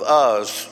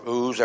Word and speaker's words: Uz: There was a man in the land of Uz, or Uz, ever how you prefer to pronounce it, whose name was Job.